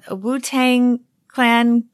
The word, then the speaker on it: Wu-Tang Clan: And I don't feel that this seems like a business book because you have everyone from Wu-Tang Clan.